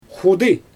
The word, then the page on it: xudɯ: Xyzyl